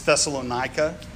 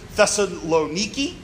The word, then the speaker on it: Thessalonica: It's Thessalonica, and I, for some reason, can't stop saying Thessalonica. Thessalonica or Thessaloniki, if you want to say it the way the Greeks would say it, Thessaloniki.